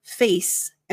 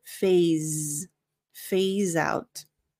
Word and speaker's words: phase: Now notice that for the pronunciation, the S sounds like a Z. So it's not face, and the PH sounds like an F. So it's not face out, but phase out.